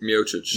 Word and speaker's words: Miocic: Miocic.